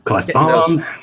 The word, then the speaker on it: Klefbom: Klefbom.